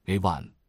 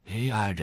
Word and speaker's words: AI: 另外，我特别想讲一个关于 AI 恐慌的故事。 – 请继续。 – 那天我听一家半导体设备公司的电话会，真的觉得又好笑又难过。 CEO 在讲 AI，结果他一直说的是 A1，你知道吧，就是牛排酱 A1 steak sauce 的那个发音。但他其实是想说 AI 人工智能。